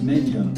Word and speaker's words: Megna: Now, I learned much later that Megna, M-E-G-N-A, is pronounced Menya if you're Italian, but we didn't know any better.